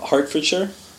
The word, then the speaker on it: Hertfordshire: The school I went to was the University of Hertfordshire. – Okay. – It's not spelled like that.